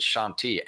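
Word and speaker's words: Chantilly: And this car at Shanti, which we are, you know, throw that out at cocktail parties, pronounce it Shanti, everybody will be impressed.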